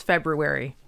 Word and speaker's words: February: My friend Amy says February. She actually pronounces the R.